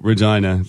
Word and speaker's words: Regina: Regina. Wow, Regina. Like, a lot to do. – Yeah. Rhymes with Queen.